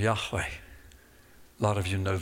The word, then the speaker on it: Yahweh: You can almost hear Yahweh. Modern Jews call it Yahweh because the Wah is pronounced almost like a V now, but in the ancient Hebrew it was pronounced like a W. Wah, Wah, Yahweh.